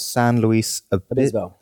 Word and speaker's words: San Luis Obispo: It's nice and sunny, and near Pismo Beach they have a town called San Luis Obispo.